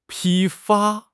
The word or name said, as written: 批发